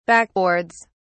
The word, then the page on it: backboards: British English